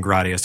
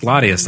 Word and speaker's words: Gladius: Although the pronunciation police got after me for saying Gradius. They're like, that's not how you pronounce Gladius. – It is like, Gradius, but though. No, I always say Gradius anyway. But. – It's pronounced Gladius. That's how it would be pronounced in Latin.